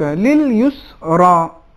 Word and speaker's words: لِلْيُسْرَى: لِلْيُسْرَى اس میں را موٹے انداز میں ادا ہوگا یعنی تفخیم ہے را میں